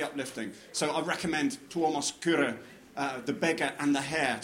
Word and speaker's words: Kyrö: I've just finished a novel called The Beggar and the Hare by a Finnish novelist called Tuomas Kyrö, which is spelled K-Y-R-O, Kyrö.